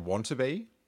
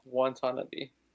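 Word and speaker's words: Watanabe: Is that how you say it? Wantaby? – Wantanabe.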